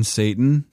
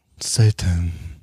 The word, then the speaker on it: Satan: It's called a Satan. – You mean Satan? – Yes. – I'm not going to do it until you say its name. – Say it right. Satan.